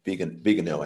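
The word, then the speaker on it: Viganò: You sent me a copy of the letter, which I had not seen, from, is it Archbishop Vigano? Is that how you say it? Vigano? – Vigano, I think.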